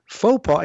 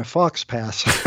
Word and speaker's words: faux pas: And they said, "It's pronounced faux pas, and you just made one."